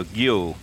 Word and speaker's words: geo: Martin Prentice from Kirsten Stromness says, we need to tell you that geo is not pronounced geo, it comes from the Norse. It's like the word go with a hard G, g, and y in the middle, and the stress on the long O, so geo.